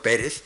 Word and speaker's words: Pérez: Pérez, yo voy a permitirme pronunciar este nombre en castellano, Pérez, pero aunque está completamente convertido en inglés por su autor.